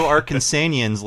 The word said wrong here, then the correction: Arkansans: How do you say that? – Arkansans. – There we go.